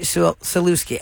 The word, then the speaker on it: Sulewski: His new bride is named Claudia Sulewski, I think that's how you say it. Sulewski, S-U-L-E-W-S-K-I.